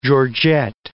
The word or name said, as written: georgette